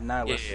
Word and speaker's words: Annihilus: Yeah, Annihilus.